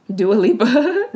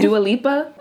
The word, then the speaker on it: Dua Lipa: Dua Lipa? – Dua Lipa. – What's her name? Is that her name? – Dua Lipa. – Okay. Dang. I didn't know if I was saying it right or not.